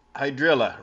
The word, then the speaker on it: hydrilla: Hydrilla rhymes with Godzilla.